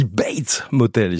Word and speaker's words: Bates Motel: Voici Bates Motel, je l'ai bien prononcé, ça va? – C'est tout à fait ça.